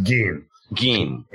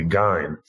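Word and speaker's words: Gein: It's pronounced Gene. G-I-N. – Yeah, apparently he, uh, his father was uh, his background was Scottish, and apparently the family name was originally McGee, uh, which is why it's pronounced like Ian. You know, if you look at, you think it should be "Gine," the way it would be in German, but it's actually a, originally a Scottish name.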